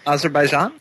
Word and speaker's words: Azerbaijan: Azerbaijan? – Yes. – Oz? Like Wizard of Oz. – Okay. We're off to see the wizard. The wonderful Wizard of Azerbaijan. – There you go. – Okay. – Azerbaijan.